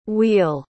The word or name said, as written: wheal